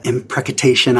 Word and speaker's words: imprecation: I don't know how to pronounce that. – Yeah. – Is that right? Or am I adding a syllable? – Imprecation. Yes. – Imprecation. Yeah. Oh, I'm adding a syllable. Imprecation. That's easier to say.